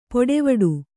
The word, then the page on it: ಪೊಡೆವಡು: poḍevaḍu